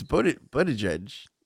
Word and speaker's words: Buttigieg: It's Buttigieg.